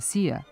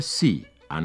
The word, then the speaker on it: Xi: U tom odeljku navedeno je da se X iz kineskog pinjina ispred slogovnog I izgovara kao S. Osim za prezime kineskog predsjednika Xi-a, to važi, na primjer, i za ime kineske novinske agencije Xinhua.